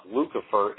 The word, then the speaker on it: Lucifer: And that word Lucifer is Latin for light bearer. It's pronounced Lucifer, and the King James translators accurately put that in there.